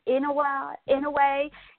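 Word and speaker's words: Inoue: Inouye, how do you say his name? – Inouye.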